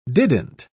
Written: didn't

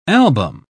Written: album

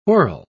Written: whorl

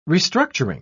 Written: restructuring

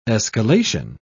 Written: escalation

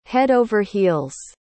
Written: head over heels

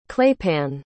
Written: claypan

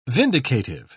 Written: vindicative

